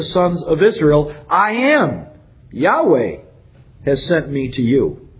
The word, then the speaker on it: Yahweh: The name by which God revealed himself was his personal name, Yahweh. That's how we pronounce it.